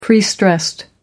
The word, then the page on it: prestressed: American English